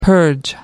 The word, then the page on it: purge: American English